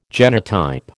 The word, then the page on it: genotype: American English